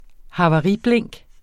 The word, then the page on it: havariblink: hɑvɑˈʁi-; ha-